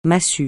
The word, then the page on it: massue: francais